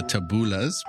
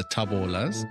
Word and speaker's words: Taboolas: In South Africa, everyone calls us the Taboolas, but it's actually pronounced the Taboolas.